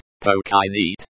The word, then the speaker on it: починить: починить. This verb is pronounced like, починить, with the stress on the second syllable.